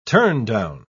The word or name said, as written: turndown